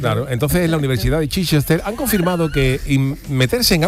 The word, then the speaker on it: Chichester: Esto Jesús, como el eso sería, se pronunciará Chichester o algo así, ¿no? – Chichester, claro, ¿no? Chichester, porque... – ¿Qué pasa? Porque le preguntas a Jesús, a Miguel Ángel y a mí, ¿no? – No, hombre, porque no, tú también tienes idea de inglés. Lo que pasa es que por el rollo del inglés, el Leicester, ¿no? Se escribe el Leicester.